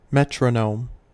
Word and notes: metronome: American English

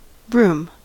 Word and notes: broom: American English

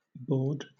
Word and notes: baud: British English

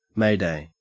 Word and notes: mayday: Australian English

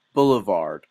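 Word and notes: boulevard: Canadian English

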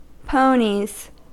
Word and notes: ponies: American English

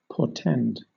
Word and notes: portend: British English

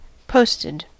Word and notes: posted: American English

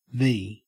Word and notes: vee: Australian English

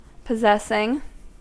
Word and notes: possessing: American English

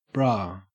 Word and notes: bra: Australian English

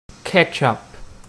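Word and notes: ketchup: Canadian English

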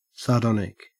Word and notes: sardonic: Australian English

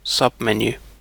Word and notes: submenu: British English

